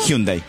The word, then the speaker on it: Hyundai: ¿Quién lo auspicia? – Hyundai. – Muy bien pronunciado.